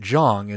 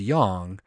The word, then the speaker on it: Jong: And he says, like a reluctant schoolboy whose teacher has questioned his homework excuse, read Erica Jong, pronouncing it Jong instead of Yong,